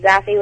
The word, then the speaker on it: Zassy: Do you pronounce it Sassy? – Not you, JV. Me. – It's from which is it Zassy from Orlando? – Oh, Orlando. – That's me, Zassy with a Z.